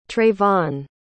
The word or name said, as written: trayvon